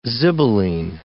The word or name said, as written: zibelline